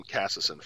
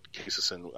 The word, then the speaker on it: Casison: I'm trying to relearn it too because I called him Cassison forever because that seems how it's spelled, but Casison I've heard is the proper pronunciation.